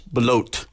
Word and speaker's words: Belote: Ashley Belote. I'm not going to butcher her name, but Ashley Belote, I think it is. – Belote. – I'm doing a silent T, and it's not a silent T, reading the book as well.